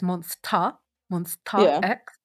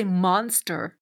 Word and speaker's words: Monsta X: See, I know it's Monsta X, but when I say it, everyone's always like, you say monster. And I'm like, not intentionally, monster.